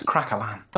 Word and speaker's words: craquelin: One of the other things that Tan did was create something called a craquelin. Is that right? Am I saying that right? – Yes, a craquelin.